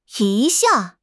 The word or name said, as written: yi1 xia4